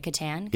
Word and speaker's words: Kattan: I think I said that right, Kattan.